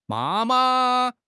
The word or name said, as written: ma1 ma5